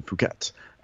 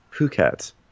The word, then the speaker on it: Phuket: So they pile into vans, they go out in Phuket. And or actually someone said it's actually pronounced Phuket. So who knows? – I've already changed my pronunciation once. Get off my ass. You're stuck with Phuket.